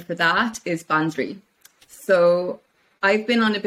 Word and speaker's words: draoi: So it's Ban, B-A-N, Dri, D-R-A-O-I, pronounced Dri, like D-R-E-E.